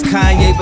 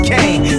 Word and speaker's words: Kanye: Now all I need is y'all to pronounce my name. It's Kanye, but some of my plastics still say Kane got family in the kin kinfolk from Motown.